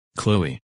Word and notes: Chloe: English

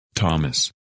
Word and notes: Thomas: English

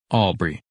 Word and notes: Aubrey: English

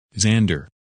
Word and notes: Xander: English

